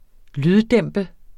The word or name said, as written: lyddæmpe